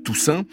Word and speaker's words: Toussaint: Le trio avait en effet enregistré cet album, Nightbirds, avec un musicien de là-bas, un des plus légendaires de la ville, Alan Toussaint, hein, ça s'écrit comme en français Toussaint.